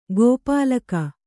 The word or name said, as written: ಗೋಪಾಲಕ